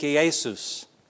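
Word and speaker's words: Iesous: The name Joshua or Yeshua is pronounced in the Greek Iesus.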